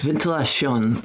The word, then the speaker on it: Ventilacion: It's Ventilacion. The I and the next letter get, jumbled together into a diphthong in Tagalog.